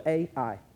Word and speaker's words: Ai: We go to this little city named Ai. You know how I know it's called that? Because it's spelled A-I, Ai.